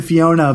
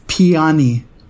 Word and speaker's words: Peony: I want to say Fiona, but it's not Fiona. It's Peony. Peony, right? That's how you say it.